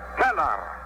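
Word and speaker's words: Völler: Se escribe Boehler y se pronuncia Feller.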